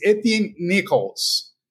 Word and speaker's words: Etienne Nichols: Today, our guest is Etienne Nichols.